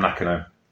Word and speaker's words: Nakano: Nakano, I should say. Pronounce it properly. Tam Nakano.